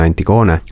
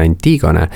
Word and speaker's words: Antigone: Ma jõudsin Antigoneni töötluste kaudu, ja ma klättin kohe ära selle hääldusasja, ma väga vabandan kõigi ees, et ma ütlen Antigone, sest on liikvel kaks hääldust Eestis, millest üks on väga levinud ja kõigile suupärane, see on Antigone, ja siis on, mis on ladinapärane hääldus ja siis on kreekapärane hääldus, mis on Antigone.